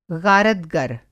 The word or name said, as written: ग़ारतगर